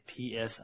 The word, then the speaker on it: Psionics: How do you say Psionics? – Psionics. – Psionics. – Yeah. – Not Psionics.